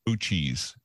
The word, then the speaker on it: Uchis: But anyway, this one, the featured artist of this week is Kali, K-A-L-I, Uchis. And if you get a chance, go to YouTube and put it in there. K-A-L-I-U-C-H-I-S, pronounced Uchis. Uchis? Oh, that would be Uchis.